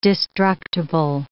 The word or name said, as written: destructible